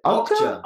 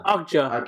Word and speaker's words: Okja: Okja. – Okja.